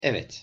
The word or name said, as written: evet